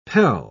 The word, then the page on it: pel: British English